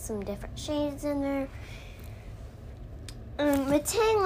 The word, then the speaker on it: Metang: Let's check the pronunciation guide. It says Metang. – Metang.